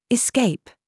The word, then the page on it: SAUCE: British English